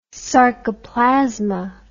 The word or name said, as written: sarcoplasma